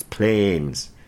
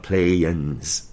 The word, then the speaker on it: planes: Apparently, I get I get way too Aussie. It's, it's planes, not planes. I don't know.